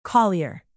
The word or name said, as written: collier